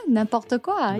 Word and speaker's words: n'importe quoi: N'importe quoi.